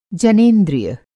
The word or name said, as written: जनेंद्रिय